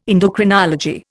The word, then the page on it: endocrinology: Indian